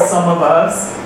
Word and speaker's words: SumOfUs: It's spelled as one word, Some of Us. Some of us, some of you know about it. S-U-M-O-F-U-S.